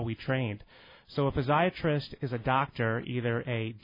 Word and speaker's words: physiatrist: And so you are a physiatrist.